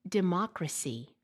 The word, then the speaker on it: democracy: Stressing the wrong syllable in a word is a frequent error. For instance, it should be mistake, democracy.